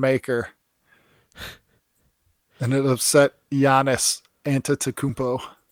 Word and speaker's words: Antetokounmpo: So I was like, okay, Antetokounmpo.